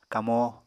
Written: kamó

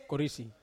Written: korisi

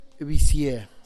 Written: bisie

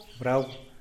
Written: brau

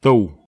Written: tow

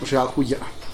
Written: biagxagulla

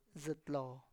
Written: zItlo

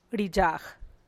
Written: ruzub kwidad

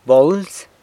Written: bolz

